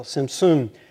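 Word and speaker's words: tzimtzum: Maybe you can help me, Lisa, because it's simsum, the accent's on the second syllable, simsum.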